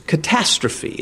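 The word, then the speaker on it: catastrophe (καταστροφή): Now, ruin here in the Greek is catastrophe. It is pronounced almost just like that.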